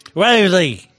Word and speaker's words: West Village: ¿Cómo se dice West Village? – West Village. – Muy bien, estamos.